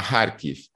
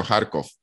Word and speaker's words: Kharkiv: Luego hay otro eje que sale de la propia Rusia, desde el norte, noreste, que sale en dirección a Kharkiv, que es, bueno, en función de cómo lo pronunciamos en ruso, en ucraniano, Kharkov, que es la segunda principal ciudad del país.